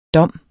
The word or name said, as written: dom